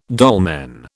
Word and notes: dolmen: British English

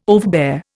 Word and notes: overbear: Indian

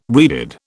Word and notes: reeded: British English

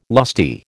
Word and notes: lusty: American English

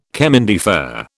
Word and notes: chemin de fer: British English

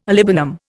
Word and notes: olibanum: Indian

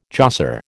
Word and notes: josser: American English

